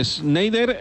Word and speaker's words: Schneider: Schneider, lo he dicho bien, vaya.